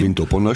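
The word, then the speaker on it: Vindobona: Windopona spricht man es aus, ja.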